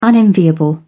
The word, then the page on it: unenviable: British English